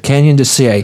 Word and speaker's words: Canyon de Chelly: Canyon de Chelly. – Canyon de Chelly.